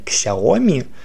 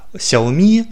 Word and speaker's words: Xiaomi: Но ударение на последний слог – это наиболее правильно. То есть, если вы хотите быть более педантичным, то называйте Xiaomi Xiaomi.